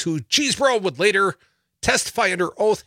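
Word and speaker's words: Chesebro: Cheesebro, pronounced Cheesebro, after the family origins in the English borough on the River Chaise.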